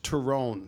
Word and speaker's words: Tyrone: So my name is Irish. It's properly pronounced Tyrone from the county of Tyrone in Ireland, but everybody calls me Ty.